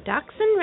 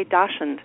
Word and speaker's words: Dachshund: I'm glad to hear that you pronounce Dachshund the way I do. So many people say Dachshund. – Oh, wow. – It's a Dachs means badger in German. – Oh, okay. – And Hund, of course, means hound, badger hound. And so okay. – If you pronounce it the German way, it's Dachshund.